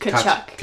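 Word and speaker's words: Tkachuk: Takachuk? – The T is silent. It is just Kachuk.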